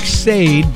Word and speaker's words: Sade: Yeah, it's S-A-D-E. It looks like Sade, but it's Sade. Had to learn how to pronounce that one back in the day.